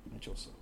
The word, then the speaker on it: Mechoso: Mechoso, okay. – Yeah, there's no accents anywhere, so you just... it's pronounced the way you read it.